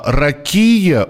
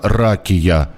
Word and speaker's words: ракия: А, ракия ударение? Нет, это неправильное ударение. Традиционно произносится ракия.